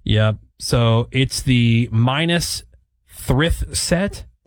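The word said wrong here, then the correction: Minas Tirith: Did I say that right? – Okay. – I don't. I don't know what Lord of the Rings. – Minastirith, which is the capital of Gondor.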